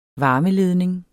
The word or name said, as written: varmeledning